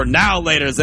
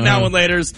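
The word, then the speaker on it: Now and Later: So I was just doing the two different pronunciations. I say now and laters. You say now laters like it's all one word.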